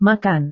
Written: makan

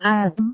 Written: atm

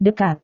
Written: dekat

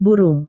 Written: burung

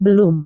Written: belum

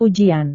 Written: ujian

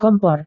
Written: kompor

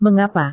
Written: mengapa

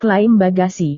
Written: klaim bagasi

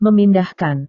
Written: memindahkan

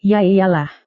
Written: yaiyalah

